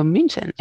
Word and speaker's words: München: El problema es cuando decimos Múnich, porque decimos, uy, esto es alemán, pues la CH será G, pero claro, no es Múnich en alemán, sino München.